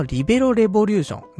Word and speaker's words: リベロ革命: このリベロレボリューションね、リベロ革命と書いてリベロレボリューションって読むんですけど、これバレーボールの漫画で今やっているビーブルースっていうサッカー漫画があるんだけど、その作者の人が書いているのがこのリベロレボリューション。